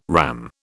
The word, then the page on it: RAM: British English